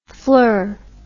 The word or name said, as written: flir